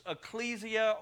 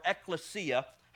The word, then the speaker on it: ekklesia: In the Greek, it's ecclesia or ekklesia, however you prefer to pronounce it. It's the same thing, tomato, tomato.